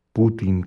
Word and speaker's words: Putin: Putin, con acento en la I, o cualquier otra cosa que se nos pueda ocurrir.